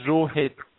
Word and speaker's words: Rohit: I'm not very possessive when it comes to pronunciation of my name, but if you want to pronounce it, you know, it's supposed to be the way you pronounce hit, H-I-T, just add the prefix as Rohit.